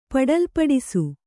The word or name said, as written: ಪಡಲ್ಪಡಿಸು